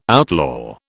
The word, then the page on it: outlaw: British English